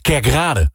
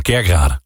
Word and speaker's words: Kerkrade: Ik ben zelf Limburger en ik erger heel erg vaak als mensen bijvoorbeeld zeggen... Kerkrade. Weet je, het is gewoon Kerkrade. – Kerkrade. – Je moet de klemtoon altijd goed leggen.